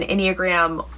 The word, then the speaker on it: Enneagram: Because according to, let's do the Enneagram first. Enneagram? – I like that. – Yes. Yeah, that's how you say it.